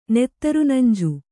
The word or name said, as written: ನೆತ್ತರುನಂಜು